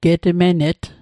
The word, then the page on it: getmenet: Mi'gmaq